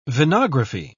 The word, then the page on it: venography: British English